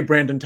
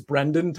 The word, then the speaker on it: Brendan: I always say Brandon Todd's name wrong. And it's like such a subtle thing. It's Brendan Todd, but I always say Brandon.